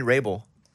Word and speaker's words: Wrabel: Mike, did you find any Ray Bull? – How do you spell it again? – W-R-A. Yeah. W-R-A-B-E-L.